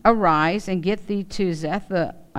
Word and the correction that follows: Zarephath: I can't even pronounce that. Somebody help me. Say it again. Zarephath. There you go, Zarephath.